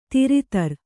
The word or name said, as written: ಥರಥರ